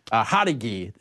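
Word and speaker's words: Jauregui: Underdog against Yasmin Yaragi, Hadagi is how we pronounce it, actually.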